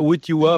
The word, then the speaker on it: Wojtyła: Mais ils ont dû dire Wojtyła, enfin, il n'avait même pas su prononcer le nom du pape.